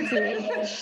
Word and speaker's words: Reiki: and you were a Reiki master. I say that right? – Okay. Reiki. Just think, you know, Ray and stuff.